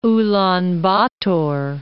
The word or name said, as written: Ulan Bator